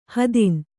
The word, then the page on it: ಹೆದ್ನ: hedna; kannada